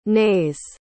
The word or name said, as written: nays